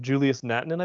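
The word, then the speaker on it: Julius Nättinen: Julius Natinen, I believe, is how you say it. – Sounds right.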